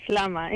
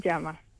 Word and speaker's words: llama: En inglés está bien, así es, Lama. En español, Llama.